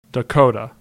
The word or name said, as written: Dakota